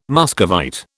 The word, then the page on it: Muscovite: British English